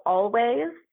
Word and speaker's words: Alvvays: Lately, I've been listening to a band called Always, but it's spelled A-L-V-V-A-Y-S.